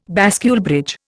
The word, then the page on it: bascule bridge: Indian